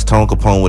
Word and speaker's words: Tone Capone: If you spell it with a C, you will not see me. Once again, it's Tone Capone with a K.